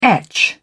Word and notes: etch: British English

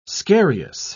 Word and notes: scarious: British English

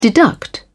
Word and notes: deduct: British English

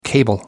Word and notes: cable: American English

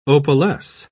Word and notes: opalesce: British English

